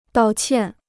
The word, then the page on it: 道歉: dào qiàn